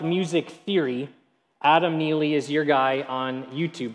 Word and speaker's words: Neely: His name is Adam Neely, with an N, not a silent K that you might expect. Adam Neely.